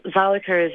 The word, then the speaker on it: Zalika: yes, Zalika is a, is quite a difficult name for um, for Australians to to pronounce, and I have had many variants of Zalika, Zaliko.